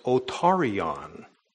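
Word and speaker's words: otarion (ὠτάριον): The word that is used for ear is pronounced otarion.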